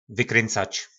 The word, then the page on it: Vykryncac: nářečí Po našimu